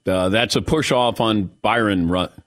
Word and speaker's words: Bryon: By the way, Brian Russell spelling his name B-R-Y-O-N probably caused him not to get that call because it was very frustrating to say his name because it looks like Byron and it's Brian.